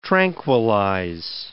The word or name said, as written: tranquilize